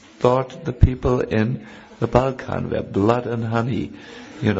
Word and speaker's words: Balkan: How do you pronounce it, please? – Balkan, yes.